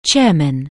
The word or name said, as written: chairman